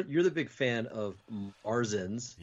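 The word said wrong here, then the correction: Märzen: No, it's Marzin. Merzen.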